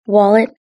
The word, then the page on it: wallet: British English